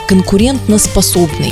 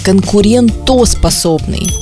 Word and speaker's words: конкурентоспособный: Например, слово слово конкурентноспособный. – А-а-а. Очень часто его произносят именно таким неверным образом. – Конкурентно. – Конкурентно. Это неправильно. Правильно это слово звучит как конкурентоспособный.